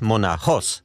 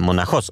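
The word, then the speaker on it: monachos (μοναχός): I patrz, jeszcze do o mnichach mówiliśmy, bo to jest mnich, to jest monachos, z takim akcentem, monachos, oksytonon, od monos, monosa, monachos.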